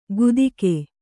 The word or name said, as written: ಗುದಿಕೆ